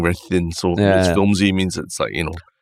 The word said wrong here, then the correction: flimsy: So only, I think, my 30s or something that I realized that I've been saying it wrong my whole life. I've been writing it and saying it wrong my whole life. It's flimsy.